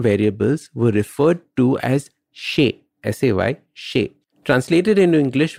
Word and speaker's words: xei: Later on, when the Al-Jabar was being translated into Spanish, the word shay, S-A-Y, which meant thing, was spelled as X-E-I and pronounced shay.